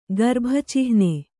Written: ಗರ್ಭಚಿಹ್ನ

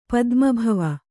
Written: ಪದ್ಮಭವ

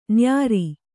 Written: ನ್ಯಾರಿ

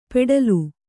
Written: ಪೆಟ್ಲು

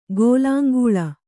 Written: ಗೆಲ್ಲಂಗೊಳ್

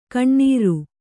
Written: ಕಣ್ಣುರಿ

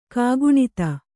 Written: ಕೋಕನದ

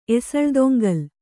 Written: ಎಸಳ್ದೊಂಗಲ್

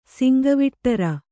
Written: ಸಿಂಗವಿಟ್ಟರ

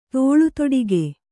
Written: ತೋಳುತೊಡಿಗೆ

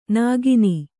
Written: ನೆಗೆಣ್ಣಿ

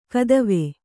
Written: ಕದವೆ

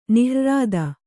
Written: ನಿಹ್ರ್ರಾದ

